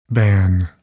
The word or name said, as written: ban